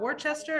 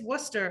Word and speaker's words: Worcester: No, it's not Worcester, it's Worcester.